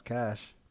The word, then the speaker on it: cache: Okay, Google. How do you pronounce? – No, you just turned on cash. My Googles. – Chris's house is lit up right now. – Yeah, I tell Google to blast all my caches every day, every night. – Oh, Google says pronounce cash.